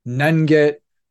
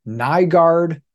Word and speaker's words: Nyenget: Specifically, the guy that he really struggles to pronounce, his last name is Nyenget, which is spelled N-Y-E-N-G-E-T. And I could totally accept Nyenget, Nyenget, which is the right way to do it, Nyenge, if you want to like add some accents to it, that would be fine.